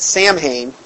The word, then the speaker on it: Samhain: If you ever see the word S-A-M-H-A-I-N, you'd think you'd say it's Samhain, but the witches pronounce it Samhain.